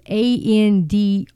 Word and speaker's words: Andrzejewski: In one recent report, Andrew Juzowski, I'm sorry, cannot say his name, folks. It is Andrzejewski.